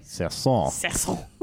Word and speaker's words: Sasson: Sasson.